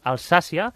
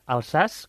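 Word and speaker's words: Alsace: A més, avui comencem amb una zona de França, que és molt bonica, frontera amb Alemanya i Suïssa, com és Alsàcia, que de fet no es pronuncia així, es pronuncia Alsàs, que ja ens ho ha dit fora de micros la nostra convidada, oi? – Sí, hem convidat la Laia Saiger.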